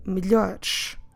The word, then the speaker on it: melhores: Now that you know how do we write them down, notice that we're dropping, when we're saying on the plural form melhores, we're dropping both es.